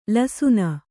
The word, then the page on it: ಲಸುನ: lasuna; Kannada